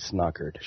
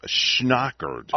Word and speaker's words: schnockered: You don't, you don't want to get snockered. – Snockered. – That's, yes, sir. – That's good. If you're gonna say it, say it right it's schnockered.